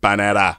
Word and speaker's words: Panetta: You gotta say it like I say it. Panetta.